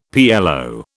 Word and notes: PLO: British English

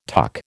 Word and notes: tuck: American English

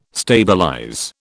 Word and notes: stabilize: British English

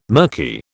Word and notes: mirky: British English

